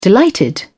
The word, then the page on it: delighted: British English